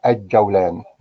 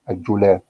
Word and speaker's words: الجولان: لكن هي الجولان بفتح ايه بفتح الجيم لكن الخطا كله بينطقها ايه الجولان